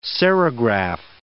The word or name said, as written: serigraph